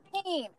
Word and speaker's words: Haim: Haim.